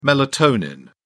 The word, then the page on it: melatonin: British English